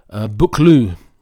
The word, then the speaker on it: Buccleuch: The public house in St. Boswell's is named after the Duke of Becklesh. Actually, it's pronounced Buccleuch, the Duke of Buccleuch.